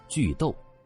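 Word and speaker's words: 句读: 第一，古人读书靠的是文言虚词断句，古人叫做句逗，这个逗就是读书的读字，它是一个多音字，在这儿叫逗，指的是语句中的停顿。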